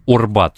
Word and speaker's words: Арбат: Орбат через буковку О, оно тогда произносилось.